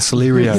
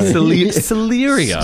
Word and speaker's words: Celerio: And they're pronouncing it with a celery. Sorry, the Celerio. – Celerio.